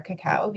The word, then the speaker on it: cacao: I learned something already because I was always pronouncing the pot as cacao. – Right. Cacao is the kind of Spanish way of saying it, and that's how most people pronounce it in the industry.